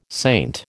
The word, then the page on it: saint: American English